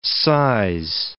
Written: size

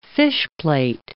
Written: fishplate

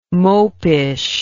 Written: mopish

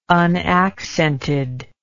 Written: unaccented

